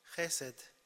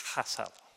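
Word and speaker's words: Chesed (חסד): He's gonna pronounce that word. – Chesed. – Úgy kell mondani. – That's how you're supposed to say it. – Chesed. Ez a szó héberben. – This Hebrew word.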